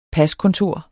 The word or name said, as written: paskontor